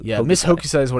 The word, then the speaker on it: Hokusai: Miss Hosokai? – Yeah, Miss Hokusai. The K comes before the S.